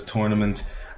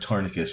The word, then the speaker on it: tourniquet: So Carlos had to act really quickly, jump in over the fence, put the tourniquet, which he mispronounces as tournament. And while he's talking to the news media, doesn't even know the word tourniquet for someone who works for the Red Cross.